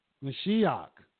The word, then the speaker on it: Mashiach: The word Messiah is a Greek word, which is in Hebrew, is pronounced, and it lost me for a second, but it's pronounced Mashiach, which means anointed.